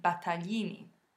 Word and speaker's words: Battaglini: Now, the family name was originally Battaglini, which is quite tricky to pronounce because it contains the sound gli. So, in today's episode, we'll be looking at how to pronounce this sound, which can take a bit of getting used to at first because it's so different to anything we have in English.